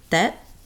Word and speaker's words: Tet: Oh, speaking of Lunar New Year, it's pronounced Tet.